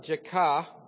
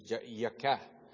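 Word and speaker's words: Jakeh: Remember, J in Hebrew is Yah, Yod. So it would be, it's literally in the Hebrew pronunciation, Jekah. Not a yay, but we usually say Jekah.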